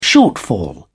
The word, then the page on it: shortfall: British English